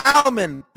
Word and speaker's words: almond: There's an L in almond. There's an L in salmon. Salmon. Almond. Egg. Say it right.